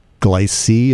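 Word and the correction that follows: Giclée: Is that the medium that you're using? – It's called G-Clay. – G-Clay.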